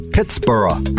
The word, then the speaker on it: Pittsburgh: originally calling the region Pittsburgh, pronounced like his native Scotland's Edinburgh.